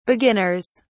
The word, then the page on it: beginners: British English